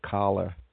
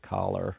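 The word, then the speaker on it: Kahler: but there is the Kala, I guess you pronounce it. Even though when you see it written, it looks to me, as an American, I would probably pronounce it collar. But in British, I guess, in British English, it sounds more like Kala.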